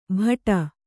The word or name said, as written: ಭಟ